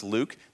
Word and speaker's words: LIWC: One of the pieces of software that allows us to do this is something called the LIWC, pronounced Luke, the Linguistic Inventory Word Count.